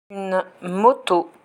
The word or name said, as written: une moto